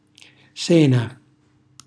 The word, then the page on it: sena: Calabrese